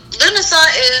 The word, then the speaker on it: Lughnasadh: So what Lunasa is, and it's spelled much differently than what it's pronounced. It's pronounced Lunasa, but it is spelled like L-U-G-H-N-A-S-A-D-H.